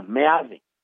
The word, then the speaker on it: Meade: Myth, José Antonio Mith. No, no, Meade. En castellano todas las letras se pronuncian. Es una característica de del castellano, salvo la H muda.